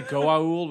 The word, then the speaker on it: Goa'uld: I wanted to say Goa'uld, which is actually, I think, how it's pronounced.